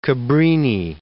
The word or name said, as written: Cabrini